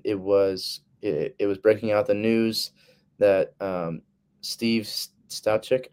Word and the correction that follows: Steichen: I don't know how to say his last name. – Steichen.